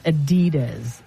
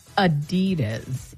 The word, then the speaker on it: Adidas: And by the way, Europeans have, you know what, reminded us, and so is the company, that Americans pronounce Adidas wrong. It is not Adidas. It is Adidas. You have to hit the hard. That is where the accent is. And there's no emphasis on D.